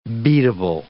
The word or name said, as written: beatable